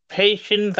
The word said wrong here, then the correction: Ephesians: I don't know how to say that, but am I saying it right? – Ephesians. Oh, the book of Ephesians.